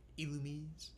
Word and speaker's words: Illumise: Illumise.